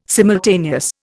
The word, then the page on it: simultaneous: Indian